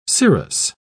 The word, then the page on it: cirrous: British English